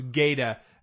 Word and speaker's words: Gaeta: Am I saying that right? – Yes. It becomes Gaeta. They mispronounce it in this series like a few times.